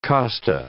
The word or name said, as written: costa